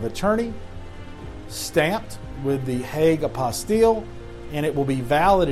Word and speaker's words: apostille: However, through the Hague Compact, you can have what's called a stamp on it, a Hague apostille, I think that's the right pronunciation. It's apostille. I-L-L-E. T-I-L-L-E. A-P- O-S-T-I-L-L-E.